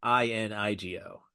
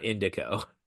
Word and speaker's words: Inigo: I no, it's spelled INIGO. Inigo. Not indico.